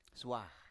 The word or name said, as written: swa